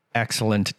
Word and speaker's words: excellent: Excellent choices. 这边有一个 excellent 的 t 哈，稍微发一下，好，不要忘记发音了。